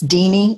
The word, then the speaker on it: Deanie: I just tell people it's Deanie, like Houdini without the who.